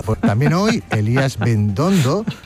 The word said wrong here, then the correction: Bendodo: Bendodo. Hay que pronunciarlo bien.